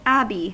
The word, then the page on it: abbey: /ˈæb.i/; English